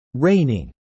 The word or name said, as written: reigning